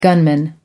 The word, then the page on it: gunman: American English